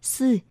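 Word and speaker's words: sư (四): vì số 4 trong tiếng Hoa được phát âm là sư và từ chết được phát âm là sự.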